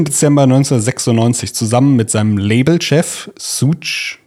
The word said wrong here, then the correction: Suge: Knight. Schuck. Schuck. Ach, wird der so ausgesprochen? Ja. S-U-G-E und dann als Schuck. Okay oh Gott, Shook Knight.